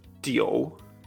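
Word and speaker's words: tio (九): I, u, yo, u, i, wei y los ejemplos, el número 9 hemos dicho que se pronuncia tio.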